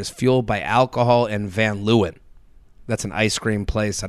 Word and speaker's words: Van Leeuwen: Van Leeuwen.